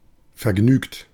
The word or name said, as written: joyeux